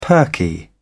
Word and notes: perky: British English

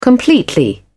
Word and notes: completely: British English

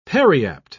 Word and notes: periapt: British English

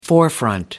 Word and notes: forefront: American English

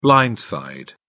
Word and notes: blind side: British English

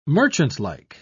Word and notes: merchantlike: British English

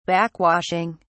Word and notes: backwashing: British English

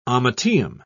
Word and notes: ommateum: British English

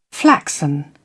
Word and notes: flaxen: British English

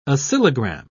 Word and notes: oscillogram: British English

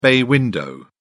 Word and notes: bay window: British English

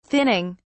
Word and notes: thinning: British English

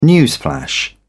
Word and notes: newsflash: British English

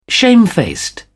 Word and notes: shamefaced: British English

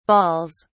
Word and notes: baals: British English

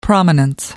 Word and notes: prominence: American English

